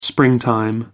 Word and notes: springtime: American English

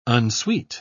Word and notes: unsweet: British English

